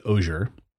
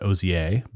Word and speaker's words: Ozier: Her name is Valerie Ozier. The French might pronounce that Ozier, but this is America, damn it.